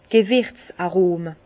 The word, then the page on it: d' Gewirz-Arome: Alsatian; Bas-Rhin; Herrlisheim